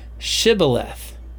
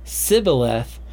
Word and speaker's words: Shibboleth: And if he replied no, they said, all right, say Shibboleth. And if he said Sibboleth, because he cannot pronounce the word correctly, they seized him and killed him at the fords of the Jordan.